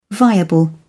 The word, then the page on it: viable: British English